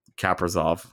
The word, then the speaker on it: Kaprizov: Kaprizov, I believe is how they pronounce it.